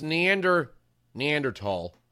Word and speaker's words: Neanderthal: So it's Neanderthal, I think is the proper way to say it.